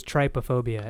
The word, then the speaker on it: trypophobia: I thought it was trypophobia. Am I wrong? – That sounds right.